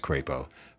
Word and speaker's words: Crapo: I know it's pronounced Crapo.